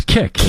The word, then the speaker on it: Kik: It's Kik, isn't it? – Okay, yeah.